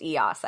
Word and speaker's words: Eos: It's pronounced Eos, I guess. – Okay, Eos.